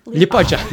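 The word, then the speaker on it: Liepāja: so um, we've got one more stop uh, in shoot am I am I uh, Lipaja? – Lipaja.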